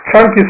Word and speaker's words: Sankhya: La C en Pali se pronuncia como CH. Cada vez que ustedes vean en la lengua Pali una C, seguida por cualquier vocal, se pronuncia como Cha, Sankhya.